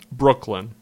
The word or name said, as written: Brooklyn